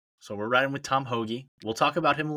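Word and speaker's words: Hoge: Give me a little fairway finder in Tom Hogue. – Oh, Tom Hoagie. I like him. I'll discuss him later on in the podcast, so we'll come back to that later. – Hoagie, that's right. I butchered it already.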